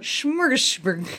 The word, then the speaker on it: Smorgasburg: Aren't you German? Can't you pronounce that? – Smorgasburg. – There we go.